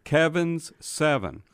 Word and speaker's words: Kevins7: And I'm really trying to pronounce that S at the end of Kevin's. – Yeah. – So Kevin's7.